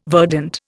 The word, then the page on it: verdant: Indian